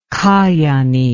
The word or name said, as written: Kajaani